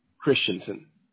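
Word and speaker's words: Christiansen: Christensen.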